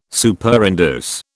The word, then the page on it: Superinduce: British English